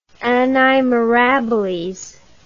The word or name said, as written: anni mirabiles